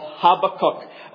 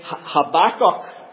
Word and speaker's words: Habakkuk: You've got to rewind, yeah, you've got to rewind 2,000 years, and you'll come to the time of Habakkuk, or as the Americans say, Habakkuk. Habakkuk. But whether you say Habakkuk or Habakkuk doesn't really matter.